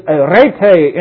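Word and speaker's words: aretē: It's arete in the Greek. That's A-R-A-T-E. That's an eta instead of an epsilon. That's a long E.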